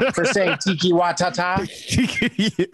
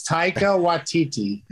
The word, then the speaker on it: Taika Waititi: For saying Tiki Watata. – That's not how it's pronounced, is it? – No, it's Taika Waititi.